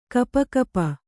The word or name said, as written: ಗಪಗಪ